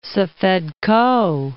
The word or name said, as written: Safed Koh